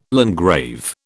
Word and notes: landgrave: British English

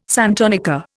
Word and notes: santonica: Indian

